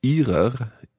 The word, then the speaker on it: Ihrer: Ihrer Familie. – So that word, Ihrer, let's hear it in good pronunciation.